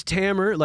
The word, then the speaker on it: Tamer: It's Tamer, like a hammer but with a T.